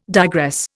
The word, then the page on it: digress: Indian